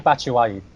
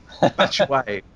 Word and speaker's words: Batshuayi: Did I even get close to that? Did I say it right? – Mishi Batshuayi. – Batshuayi. I think I added like three extra syllables.